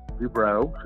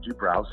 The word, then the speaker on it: Dobrow: And I have a special guest today, and it is a Mr. Larry Dobrow, who is a Dobrow.